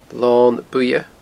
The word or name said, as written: Lon Buidhe